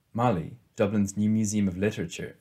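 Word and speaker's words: MoLI: Mali is pronounced Mali in a nod to Molly Bloom, and multimedia displays and inclusive exhibitions keep things relevant and zippy.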